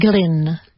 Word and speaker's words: Glynn: Glynn. – Glynn. With one N or two? – Two. – Glynn. – G-L-Y. – Like John Glynn. – No. G-L-Y-N-N. – Ah. Pronunciation's identical. Well.